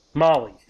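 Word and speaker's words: Mahle: I've been doing this for so long and I still don't know how to say his last name. Molly, okay. I swear someone's told me that every single time, and I never remember it.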